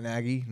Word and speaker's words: Nagy: Nagy will be fired. That's all I have to say. – What? – Nagy will be fired. – Matt Nagy? Nagy? – No, he got the name right.